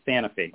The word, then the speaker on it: Sanofi: Yeah, I think there's actually two official ways to pronounce it. I say Sanofi.